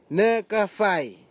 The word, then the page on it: nikefai: Nafe; Tanna